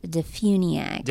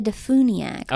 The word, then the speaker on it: DeFuniak: Well, you know, it just that's also like that you correctly pronounced Defuniak. – Did I? I didn't know. – Yes. – What's the wrong way? – People say Defuniak all the time, and it drives me crazy.